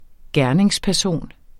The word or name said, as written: gerningsperson